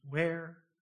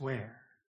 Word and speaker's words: were: And such, W-E-R-E. Now I think that's supposed to be pronounced where. I usually say were, but it's where.